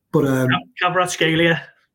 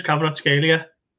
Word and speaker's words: Kvaratskhelia: But Kvaratskhelia.